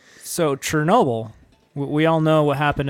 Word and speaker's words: Chernobyl: It's Chernobyl. But you Chernobyl. Say it like with a T, but like put a little H in there.